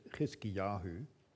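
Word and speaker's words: Chizkiyahu (חזקיהו): In those days, Hezekiah, or as he himself would have pronounced it, Chizkiyahu, became sick and was at the point of death.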